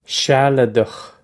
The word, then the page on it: sealadach: Irish